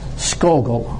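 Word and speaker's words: Scougal: Henry, and I hope he pronounces it, Skogel. I'm S-C-O-U-G-A-L.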